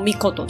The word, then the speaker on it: 命: 字后面的，它念作 mikoto。有时候是写作尊啊，尊重的尊。你会在很多的神话故事或是一些历史故事作品中看到什么什么命，什么什么尊的哦，大部分都是这个字。例如说呢，须佐之男，你去网络上搜寻的话，你可能会看到须佐之男命这个字，那或者是须佐之男尊都有可能哦。所以这个命或者是尊，它都是读作 mikoto 这个字。那那这是什么意思呢？其实就是一个尊称啊，大部分是加在神明或者是天皇啊、皇室名字的后面。